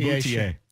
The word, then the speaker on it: Boutier: Boutier. – Yes. – Yes. I always rely on you for pronunciation. – Celine Boutier.